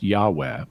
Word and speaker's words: Yahweh: One of them would mean that if you say just the name of God, the four letters, the so-called tetragrammaton, which in English is commonly pronounced Yahweh,